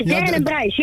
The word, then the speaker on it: Darren en Bryce: Spreek je de naam op zijn Engels uit? Darren en Bryce? – Hoe je het uitspreekt? Ja, Darren en Bryce.